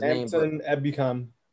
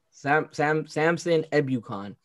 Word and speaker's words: Samson Ebukam: I forgot how to pronounce his Samson Ebukam. – Samson Ebukam.